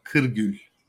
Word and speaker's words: Kirgul: Mustafa Kirgul.